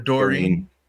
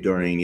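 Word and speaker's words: Doeren: I don't know how to pronounce his last name. – Dave Doreen. – Yeah. Dave Doreen, yeah.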